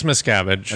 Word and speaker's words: Miscavige: And that's when, how do you say it? Miscavige? – Miscavige. – Miscavige? – Is it? I thought it was like Miscavige or something. – But no, it's Miscavige.